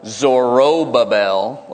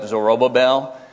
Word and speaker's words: Zorobabel: There it's spelled Zorobabel, like Zorro, but Babel at the end of it, Zorobabel. Zerubbabel beget Abiud, and Abiud beget Eliakim, and Eliakim beget Azor.